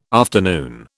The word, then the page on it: afternoon: British English